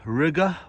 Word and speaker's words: Riga: It's about, 60, 70 kilometers away from Riga, which rhymes with nigga.